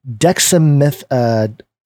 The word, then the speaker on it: dexamethasone: such as the use of the steroid dexamethasone, is that how you say it? Mm-hmm.